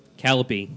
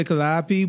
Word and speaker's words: Calliope: Calliope, right? Is that what he said? – Some people say Calliope, but it's pronounced Calliope.